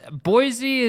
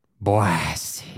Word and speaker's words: Boise: You got to say it right. – Boise. – No. You're being a douche now. – How am I? I'm being a douche. – Boise.